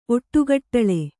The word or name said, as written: ಒಟ್ಟುಗಟ್ಟಲೆ